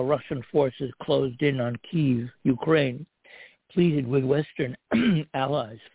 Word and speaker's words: Kyiv: Kyiv, which is the way they pronounce it in Ukrainian as opposed to Kiev, which is Russian.